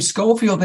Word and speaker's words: Schofield: But in any event, my last name, Schofield, they couldn't spell it. So I would say it's very easy. Just remember, it's S-C-H-O-F-I-E-L-D. The H is silent like the P in swimming.